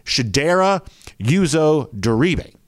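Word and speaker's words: Chidera Uzo-Diribe: Chip says on Twitter, for your information on Georgia's latest addition to the coaching staff, the correct pronunciation per the team is Shedera Uzodiribe.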